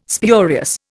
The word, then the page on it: spurious: Indian